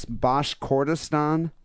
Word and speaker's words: Bashkortostan: A court in the Russian Internal Republic of, I don't even know how to say this, Bashkortostan.